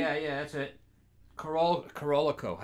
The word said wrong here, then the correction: Carolco: How do you say it? – Corolco.